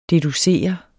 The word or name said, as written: deducere